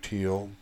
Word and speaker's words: Teal: When I was born Teal, and people told my mother that people would misspell it and call me Neil. It was T-I-E-L, and so she changed it to Teal, T-E-A-L, which happens to be a color.